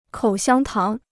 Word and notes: 口香糖: kǒu xiāng táng

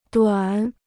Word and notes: 短: duǎn

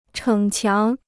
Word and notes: 逞强: chěng qiáng

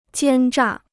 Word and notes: 奸诈: jiān zhà

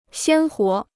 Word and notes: 鲜活: xiān huó

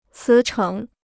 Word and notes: 辞呈: cí chéng

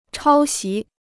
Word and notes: 抄袭: chāo xí

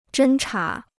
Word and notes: 侦察: zhēn chá

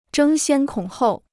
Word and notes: 争先恐后: zhēng xiān kǒng hòu

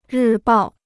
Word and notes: 日报: rì bào

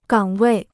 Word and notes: 岗位: gǎng wèi

